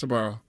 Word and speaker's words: Sbarro: Is that how you say it? – Yes, Sbarro.